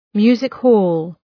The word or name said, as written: music-hall